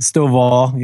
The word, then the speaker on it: Stovall: Stovall, yeah.